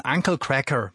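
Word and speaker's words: Uncle Kracker: Haben jetzt extra ein bisschen Uncle Cracker, bisschen englischer ausgesprochen, bisschen amerikanischer. Wenn man Onkel Cracker draus macht, klingt es total doof.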